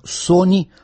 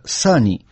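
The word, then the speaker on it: Sonny: Его имя писалось как Сони, а произносилось как Сани.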